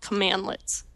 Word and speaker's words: cmdlet: In PowerShell, these get dash member and get dash something, those are called commandlets. When you see that written out, it's spelled CMDLET, but it's pronounced commandlet.